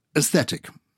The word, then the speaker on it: aesthetic: So, aesthetic. – Mm-hmm. – You're not actually pronouncing the A, are you?